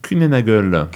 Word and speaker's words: Kuehne+Nagel: QN plus Nagel, on prononce ça comment ? – Kuehne+Nagel.